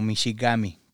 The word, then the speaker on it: mishigami: Es una palabra que se escribe O-J-I-B de bueno W, e, pero suena este, como Michigami, que significa agua grande o lago grande.